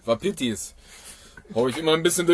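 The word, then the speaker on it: Wapiti: Also gestern gerade hatten wir richtig viele, wie ich es immer ausspreche, Waipitis, obwohl sie Wapitis heißen.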